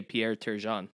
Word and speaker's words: Pierre Turgeon: No, I'm just looking at this Pierre Turgeon paperweight, and I'm like, man, I would love to hear say Pierre Turgeon. – Pierre Turgeon. – There we go. – That's how you say it.